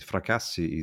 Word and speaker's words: Fracassi: F-R-A-C? – Yeah, okay, we spell it like this. Fracassi is F-R-A-C-A-S-S-I.